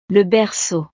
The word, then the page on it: le berceau: frances